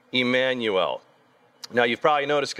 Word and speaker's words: Immanuel: I think they spelled it with the E because it would be more appropriately pronounced Immanuel. You know, in a lot of different languages, that I is pronounced as an E sound. So, same pronunciation, just a different spelling.